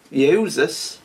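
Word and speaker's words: Iēsous: But because this Lord of Righteousness is as good as his title, and because this man is as good as his name, Yeshua, Joshua, in Greek pronounced Yehuzas, Jesus, which means Yahweh's salvation, death could not hold him.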